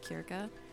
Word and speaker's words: Circe: If you've never read Circe, or if you've never heard of Circe, technically her name is pronounced something along the lines of Kirka, if you're going off Greek mythology, but I really recommend it.